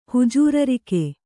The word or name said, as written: ಹುಜೂರರಿಕೆ